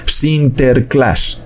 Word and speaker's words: Sinterklaas: Porque en el idioma irlandés u holandés se le decía a ese San Nicolás, o sea, así era como se pronunciaba Sinterklaas. Eso pronunciaba San Nicolás. Entonces ese Sinterklaas fue transliterado en el idioma inglés a Santa Claus.